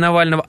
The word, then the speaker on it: Навального: Основателя фонда борьбы с коррупцией Алексея Навального атаковали неизвестные, метнув в него пару тортов.